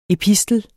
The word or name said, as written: epistel